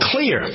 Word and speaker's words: Xlear: So I introduced to you something called Clear. X-L-E-A-R, pronounced clear, but spelled with an X.